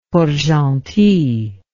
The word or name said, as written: Port-Gentil